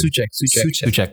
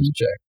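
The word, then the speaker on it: Souček: Suchek. – I'm so bad with the name. – Suchek.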